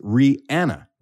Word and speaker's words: Rihanna: Everybody thinks her name is Rihanna, but it's not. – What is it? – Her name is pronounced Rihanna. – Rihanna? – Rihanna. – The famous Rihanna? – And that's how her name is pronounced, but everyone says Rihanna.